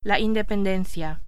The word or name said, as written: independencia